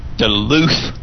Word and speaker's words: Duluth: I'm going to say it right, honey. I promise. – Okay. – Duluth, Minnesota. – Very good.